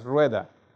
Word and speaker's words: Rueda: You said it correctly, sir. It's Rueda. You see, in Spanish, the R is a little difficult to pronounce,